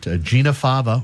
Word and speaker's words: Gina Fava: It is Gina Fava, just like the fava bean, yes.